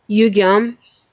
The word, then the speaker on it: Yugyeom: Yugyeom.